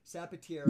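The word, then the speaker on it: Sapateiro: You can, I know go to the Instagram, and it's S-A-P-A-T-E-I-R-O. I think I'm saying it right. I'm probably butchering it. Zapatero. – Yeah, you totally did. No, that was right.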